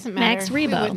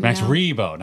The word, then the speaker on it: Max Rebo: And of course, the greatest Muppet of all time, Max Grebo. – Yes. – Mm-hmm. – Is that right? Did I say his name right? Yep. No. Did I? – Max Rebo. – Max Rebo, not Grebo.